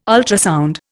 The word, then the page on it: ultrasound: Indian